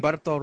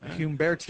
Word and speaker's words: Humberto: Humberto Ramos. – Okay, are you sure you said that correctly? – Yes. – Yep. – Humberto.